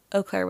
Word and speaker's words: Eau Claire: And then Riley said, Eau Claire, Wisconsin.